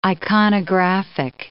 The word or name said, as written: iconographic